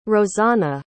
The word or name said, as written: rosana